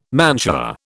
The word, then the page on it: mantua: British English